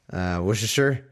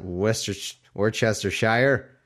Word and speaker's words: Worcestershire: You don't know that it's actually pronounced Worcestershire. Worcestershire sauce. It's Worcestershire sauce, not Worcestershire.